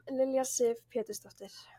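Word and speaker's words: Lilja Sif Pétursdóttir: Lilja Sif Petursdottir.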